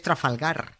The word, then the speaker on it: Trafalgar: Que no es Trafalgar, que es Trafalgar. Que una cosa es que los guiris no sepan decirlo, que en su derecho están y no pasa nada, pero otra muy distinta, que un español snob lo pronuncie con acento inglés comiéndose todas las R's.